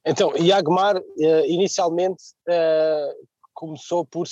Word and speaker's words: Yagmar: Uh, e sim, estava certo, é, é assim que se pronuncia é Iagmar.